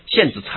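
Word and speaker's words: 蚬子: 啊，不，一个虫字旁旁边一个剑呢，线啊，线子禅师啊，他什么？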